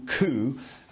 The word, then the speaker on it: coup: We also have coups, a military coup or a political coup. And coup is spelled C-O-U-P.